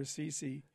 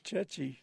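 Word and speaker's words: ceci: I always thought they were Sisi. It's chechi.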